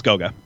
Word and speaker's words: Goga: I don't know how to say this, even though I've been saying Goga, luckily. So I watched some Draft Express videos. It's Goga.